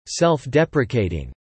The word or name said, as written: self-deprecating